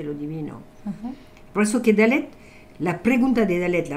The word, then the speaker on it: Dalet: Ha sacado Elvira número 4, que eh, se pronuncia Dalet, d a l e t a -A -H,